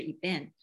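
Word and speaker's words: eben (אבן): 那他这边为什么要用石头来用这句经文呢因为石头和这个儿子在希伯来语里面它的发音是很相近的。那儿子的话就是 ben 石头就是 eben，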